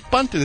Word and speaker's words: Bunton: Emma Bunton. Ha! Baby Spice of the Spice Girls. Why do you laugh every time I say her name Bunton, Mike? – Similar. – Yeah, but it's not. It's Bunton.